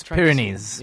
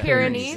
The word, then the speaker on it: Pyrenees: I think it's Pyrenees. – Yeah. Pyrenees, yeah.